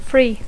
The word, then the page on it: free: English